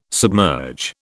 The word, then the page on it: submerge: British English